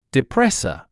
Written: depressor

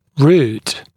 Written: root

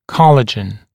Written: collagen

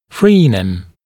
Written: fraenum, frenum

